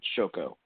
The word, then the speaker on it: Shoko: I'm pretty sure I did, but that's how you pronounce it, everyone. Shoko.